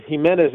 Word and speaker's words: Jimenez: So with Jimenez, it's Jimenez. The emphasis is on men.